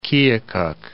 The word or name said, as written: Keokuk